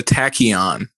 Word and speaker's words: tachyon: He's also narrated and writer for a Viceland TV series called Payday and Mr. Tycon. And did I say that right? – It's a tachyon, but tachyon. It's like, I mean, it's not like a word or anything.